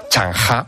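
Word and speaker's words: Zheng He: Es un personaje que se llama Shen He. Bueno, en realidad, si lo tengo que pronunciar a la china, es Chang Ha.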